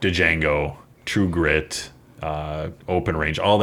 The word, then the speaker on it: Django: Now, Django is obviously much more just brutal. – The D is silent. It's just Django.